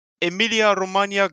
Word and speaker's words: Emilia-Romagna: Emilia-Romagna Grand Prix 2020.